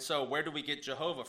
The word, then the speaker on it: Jehovah: And so in German, the J is pronounced like a Y. So in German, it would sound like Yahweh, but it's a combination of Yahweh and Adonai. And so what happens is when you then translate that into English, the J stayed, but we pronounce J differently in English, pronounce it with a G sound. So Jehovah.